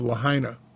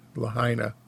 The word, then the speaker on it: Lahaina: Well, high winds from this hurricane drove the fire rapidly westward into the town of Lahaina. I hope I'm pronouncing that right. It's L-A-H-A-I-N-A, Lahaina, or Lahaina.